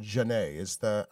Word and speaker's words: jinete: So, first of all, what eventually becomes called jinete, how is that pronounced? – Jinete. Well, in Spanish, the word is jinete. – Jinete, right. – In Catalan, it would be jinete.